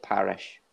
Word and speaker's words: Parish: I mispronounced Parish. Through an L on the instead of the R. – And what was it? Devine? – Rhys Devine or Elliot Parish off the bench.